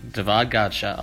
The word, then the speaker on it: Godchaux: Davon Godchaux.